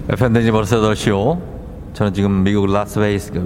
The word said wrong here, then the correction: 라스베이거스: 아유, 발음이 안 됩니다. 미국 라스베이거스로 향하는 비행기 안에 있습니다.